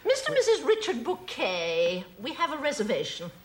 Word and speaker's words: Bucket: That's spelled B-U-C-K-E-T. – Oh, bucket. Oh, yes, we have a bucket. – Pronounced bouquet, dear.